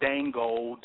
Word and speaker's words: Django: The movie is not called Django, Durango. It's called Django. – Django. – Right. – Django. Right. – You know, the D is silent.